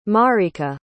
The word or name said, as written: marrica